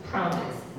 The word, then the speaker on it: promise: promise นะคะสัญญาถูกไหมคะจดทันนะคะเวลาออกเสียงภาษาอังกฤษนะคะ stress พยางค์แรกนะคะ promise